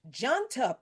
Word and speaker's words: Janta: This resulted in the creation of what is now a Gen Z-led cockroach Janta party. I think that's how you pronounce that word, Janta, J-A-N-T-A party.